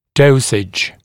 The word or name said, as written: dosage